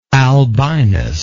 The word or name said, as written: albinus